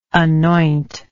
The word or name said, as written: anointer